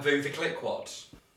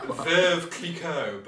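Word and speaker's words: Veuve Clicquot: And Vouva Cliquot. – What are you saying? – Vouva Cliquot. – Vouva Cliquot. – It's like Veuve Cliquot, but it's spelled V-U-V-E. V-E-U-V-E. – Uh-huh. – C-L-I-C-Q-U-O-T.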